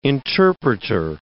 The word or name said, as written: interpreter